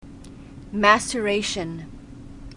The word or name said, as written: maceration